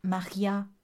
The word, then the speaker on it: Marja: Alors, c'est Maria en prononciation néerlandaise, mais ça s'écrit Marja. J'avais